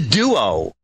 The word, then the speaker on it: Duo: It is the it's the Duo. – The Duo. – It is the Energizer Duo charger. – All right. Excellent. – D-U-O, in case my pronunciation, you thought it might be like morning dew.